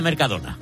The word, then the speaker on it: Mercadona: ¿Cómo se pronuncia Mercadona? – Mercadona.